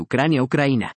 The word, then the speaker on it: Ucraína: Nikolai y su esposa Lyudmila pertenecían a sendas familias de Ucrania-Ucraína, según la pronunciación local, expresa el doctor Benavent.